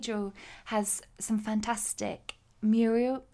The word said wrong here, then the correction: murals: I kept saying this wrong. – Muriel. Muriel is a person. Murals.